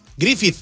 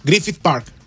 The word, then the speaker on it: Griffith: Griffith. – Griffith, ajá. – Griffith Park. – Griffith Park. Griffith Park. – Sí. – A ver, la TH. Griffith Park. Griffith Park. – Sí, Griffith.